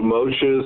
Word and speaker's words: Moshe: Moses is alluded to by the word what, in Hebrew, ma, for we are taught that the name Moses, in Hebrew, Moshe is spelled Mem Shin He, can be seen as an abbreviation for the first three words in the verse from Ecclesiastes.